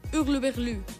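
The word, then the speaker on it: hurluberlu: Hurlubergru. – No es pronuncia l'H del principi, en francès és estranyot, i en aquest cas, excèntric o esbojarrat, és Hurlubergru.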